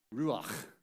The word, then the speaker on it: Ruach: If you want to speak Hebrew, make sure no one's sitting right in front of you. All right? Ruach. All right? There you go. You got to say it like that.